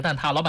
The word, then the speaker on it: 蛋挞: 对，但是你平时到店里买，说老板我要几个蛋挞，老板可能会一下反应不过来。就平时我们说的那个蛋挞啊，它就是正确发音叫蛋挞。